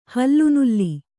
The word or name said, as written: ಹಲಿನೀಲ